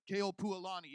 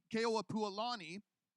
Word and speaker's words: Keopuolani: Who then take over the kingdom, his son Kamehameha II and his wife, somebody help me with her name very briefly, Keopuolani, yeah, thank you. Ahumanu came later, but Keopuolani, did I say it right? I think I got it.